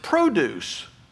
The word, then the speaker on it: produce: And in that case, we put our emphasis on a different syllable to pronounce it produce. Like a produce stand or a produce section of the grocery store.